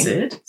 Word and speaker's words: Cyd: So the dancer in that piece is a woman called, it's spelled C-Y-D. That's her name. – Sid? – Sid. Charis. – Kid. C-Y-D Charis? Yeah. Oh, sorry. – That's her name. – I thought that's how you're pronouncing C-Y-D.